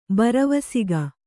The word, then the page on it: ಭರವಸಿಕೆ: bharavasike